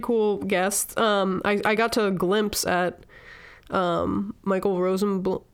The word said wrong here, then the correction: Rosenbaum: How do you pronounce it? Rosenblum? Rosenbaum? – Yeah, Rosenbaum. – Rosenbaum.